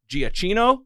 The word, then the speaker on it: Giacchino: music by Michael Giacchino.